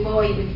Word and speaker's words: Bowe: Bowie, they bring Bowie home signs everywhere. – Right, right. – Right. – But they pronounce it Bow.